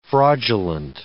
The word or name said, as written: fraudulent